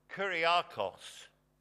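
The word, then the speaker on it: kuriakos (κυριακός): In the early Greek, it was pronounced kuriakos, meaning pertaining to the Lord.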